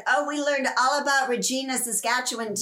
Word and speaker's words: Regina: So but it's pronounced Regina. – It's called Regina. Okay.